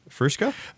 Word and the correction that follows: fuchsia: Uh, fuchsia.